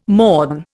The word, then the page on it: mourn: Indian